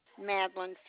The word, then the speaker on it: Madeline: Madeline Fields. – Can you spell your first name for me, please? – M-A-D-E-L-I-N-E. – L-I-N-E.